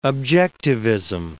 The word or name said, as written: objectivism